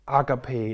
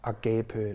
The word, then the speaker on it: agape: Det er det græske ord agape eller agape eller agape, som nogen vil udtale det.